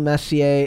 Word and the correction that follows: Messi: is it Messi? – I think it's Messi, but again, it's pronounced Messi.